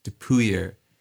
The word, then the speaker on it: Dupuyer: And so I was convinced that that town was called Dupier, and I wrote a whole song about it. I found out after I recorded it and released it and everything, this guy writes to me and he says, I really, really like the song. One small complaint, it's pronounced Dupuyer.